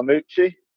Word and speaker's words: Lamouchi: In contention for manager of the year this year is Nottingham Forest manager Sabri Lamouchi, or Lamouchi, I said that right.